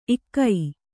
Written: ಇಕೊ

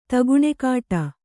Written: ತಗುಣೆಕಾಟ

